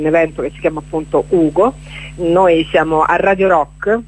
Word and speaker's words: UGO: Allora, sì, Ugo è così come si pronuncia, come Fantozzi, ma è un acronimo che non vi stiamo a dire, perché lo troverete su Facebook.